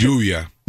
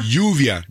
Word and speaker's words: lluvia: How do you say rain in Spanish? – Lluvia. – Yuya? – Yuvia. – Yuvia. – L-L-U-V-I-A. Yuvia.